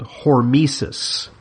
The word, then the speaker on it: hormesis: But there is, in medicine and science, something called hormesis. Hormesis is spelled H-O-R-M-E-S-I-S.